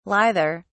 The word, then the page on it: lither: British English